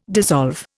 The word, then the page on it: dissolve: Indian